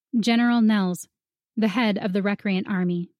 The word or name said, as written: General Nels